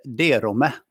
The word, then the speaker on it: Derome: Det heter Derome man ska tänka Lindome, Skogome, Derome. Samma uttal.